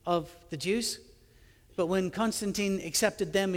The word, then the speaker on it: Constantine: After Christianity began to blossom, and especially after Constantine, and yes, he would have pronounced his name Constantin, but Constantine, don't want to mess that up for everybody, accepted Christianity as the official religion, that's when the big break between Christians and Jews was formal and final.